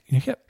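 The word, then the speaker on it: Nikic: We've got Chris, how do you say that last name? Nickett? – Nickett.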